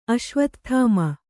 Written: ಅಶ್ವತ್ಥಾಮ

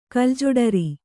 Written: ಕಲ್ಜೊಡರಿ

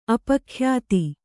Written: ಅಪಖ್ಯಾತಿ

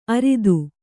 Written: ಅರದ

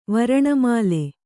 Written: ವರ್ಣಮಾಲೆ

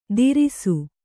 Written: ತೀರಿಸು

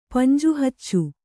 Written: ಪಂಜುಹಚ್ಚು

